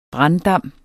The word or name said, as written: branddam